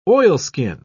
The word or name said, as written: oilskin